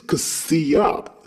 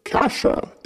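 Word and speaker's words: Cassia: Her flowy hair also resembles the two S's put together in the spelling of her name, and the pronunciation that fits that better is Kasia. Such a beautiful name to fit a beautiful poetic character. Kasha is too dry for her. These are my concerns as a fan. Please, Mrs. Condi, consider the name change. Many fans beside myself also thought it was Kasia.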